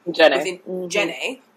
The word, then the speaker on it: Djenné: And so the Black Rock compound was designed and conceived by Senegalese architect Habib Djenné. I think I'm pronouncing it right, D-J-E-N-N-E. – Djenné.